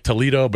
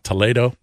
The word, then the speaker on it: Toledo: We went to a town called Toledo, spelled like Toledo, but it's not. It's pronounced Toledo.